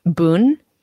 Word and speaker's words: bún: So one of my favorite dishes in the world is Vietnamese bún. It's spelled like B-U-N. I also love pho. So like if it's cold, I want pho. And if it's not cold, I want bún.